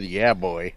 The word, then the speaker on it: Jawohl: but it's not even spelled that way. It's spelled J A W O H L. There's no V in it. boy. – Wait a minute. Spell it again. – J A W O H L. That's it right there.